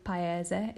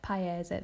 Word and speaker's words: paese: So to say country, is paese, okay, il paese, it's a masculine verb, il paese, the country.